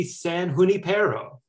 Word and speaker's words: San Junipero: And actually, although I've always never pronounced it this way, it should be San Junipero, right? It's a Spanish name, like La Jolla and places like that, right? The J should be like a H.